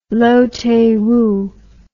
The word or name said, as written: Loh Tae Woo